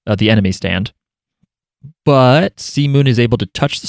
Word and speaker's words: C-Moon: Yeah, so it's this thing where Jolyne is like choking C-Moon and uh by the way, every time I say C-Moon, I think I'm going to say Seamen, but I don't. You're all lucky. Just it's pronounced come on. It's true. It's not Seamen, it's come on.